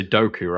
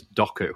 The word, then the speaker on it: Doku: You're confusing me because you pronounce it differently as American. You say Doku, right? And for us over here, it's Doku.